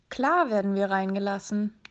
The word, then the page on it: reingelassen werden: German